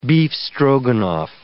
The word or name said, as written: beef Stroganoff